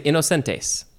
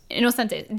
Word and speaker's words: Inocentes: they would say Inocentes. – Inocentes. Dia de los Santos Inocentes.